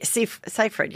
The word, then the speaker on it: Seyfried: Not Amanda's, how do you say it? Seyfried? – Yeah, Seyfried, yeah.